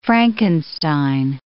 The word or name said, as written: Frankenstein